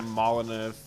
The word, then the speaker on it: Molyneux: Was that Peter Molyneux? – Yeah, I believe. – Is that how you pronounce his name? – Yeah. – Peter Molyneux.